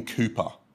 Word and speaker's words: Cowper: He had a friend called William Cooper, spelt Cowper, but you pronounce it Cooper.